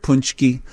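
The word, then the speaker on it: pączki: Is it like a soft N in there, punchki? – Yeah, like P-O-O-N-C-H-K-I. – Punchki. – Or K-E-E. – Yeah, right, exactly. – Punchki.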